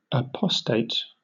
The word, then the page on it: apostate: British English